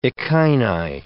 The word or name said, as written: echini